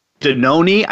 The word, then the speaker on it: Danone: And I was Danone, I think I'm pronouncing that right. D-A-N-O-N-E. D-A-N. Let me just say that. D-A-N-O-N-E. Danone. I guess that's Danone. Mm. Do you have any idea what that is? – Or Danon? Danon? – Danon? No. Or Danon? But D-A-N-O-N-E.